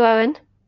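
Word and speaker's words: Eoin: Thank you, Owen.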